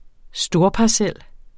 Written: storparcel

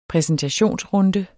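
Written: præsentationsrunde

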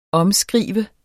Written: omskrive

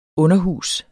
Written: UNDERHUSETS

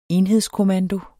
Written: enhedskommando

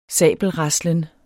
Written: SABELRASLEN

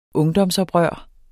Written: ungdomsoprør

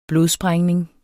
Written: blodsprængning

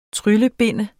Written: TRYLLEBIND

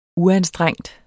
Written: uanstrengt

